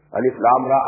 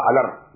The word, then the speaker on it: alif lam ra: These are pronounced with separately, and so they are called, they are the broken into pieces. Not pronounced as alif lam mim alam, no. Alif lam mim. Alif lam ra alar, no.